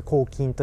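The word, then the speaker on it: 口琴: 口で奏でる楽器です、ねはいはい、口の琴と書いて抗菌というふうに言われ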